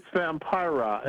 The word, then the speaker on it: Vampira: It's Vampira.